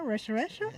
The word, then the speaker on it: Rochester: ¿Lo dije bien? ¿Rochester? Ah, muy bien.